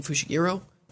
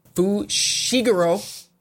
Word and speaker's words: Fushiguro: At the high school, on the track field, Maki and Panda are training Kuwasaki and Fushiguro. Fushiguro.